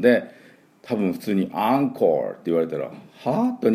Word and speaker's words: encore: で、スペルが ENCORE ですね。普通だったら EN だから N って読みそうなところなんですけど、これでアンって読みまして、アンコール。